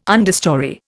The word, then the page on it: understorey: Indian